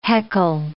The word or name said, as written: heckle